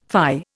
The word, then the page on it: fie: Indian